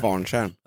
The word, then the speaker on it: Kvarntjärn: Kvarntjärn. – Mm. – Var det rätt uttalat då? – Ja. – Ja, shit, jag lär mig. – Kvarntjärn.